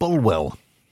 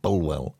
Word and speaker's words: Bulwell: And she said, it's a small town called Bulwell. That's how it's spelled, all right, Bulwell. She said, but it's pronounced Bool by us locals.